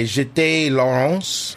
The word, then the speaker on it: Jeté Laurence: His daughter, Ellie, which everyone calls Elle, which I think is very cute, played by Jeté Laurence, French kid. – Good pronunciation there.